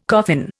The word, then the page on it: coffin: Indian